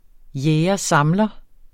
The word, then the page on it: jæger-samler: ˈjεːjʌˈsɑmlʌ; jεːjʌˈsɑmlʌ